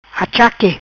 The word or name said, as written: Achaque